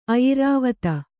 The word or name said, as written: ಆಯುರ್ವೇದಿ